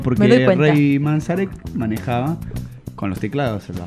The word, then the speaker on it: Manzarek: yo quiero que vos me corrijas, o que lo digas vos de última, si sabes pronunciarlo bien, al resto de los Doors. – Ray Manzarek. – Manzarek.